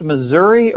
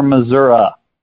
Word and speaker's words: Missouri: By the way, is it pronounced Missouri or Missouri? – Well, it depends on whether you live in the upper part of Missouri or the lower part of Missouri. – I see. Okay. So it's the same place depending with a different pronunciation depending on where you are. Okay. – Well, so really, it's the local vernacular versus the educated vernacular.